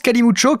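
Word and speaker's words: calimocho: Alors je prononce Kalimucho car c'est comme cela que l'on me l'a présenté la première fois,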